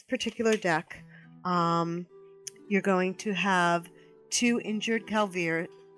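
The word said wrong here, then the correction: Kvaldir: How do you pronounce it? – Kavaldir. – Kavaldir. Thank you.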